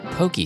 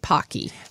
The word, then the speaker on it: Pocky: And today we're talking about pokey sticks. – And I'm so glad you said it first, because I would have pronounced it pocky.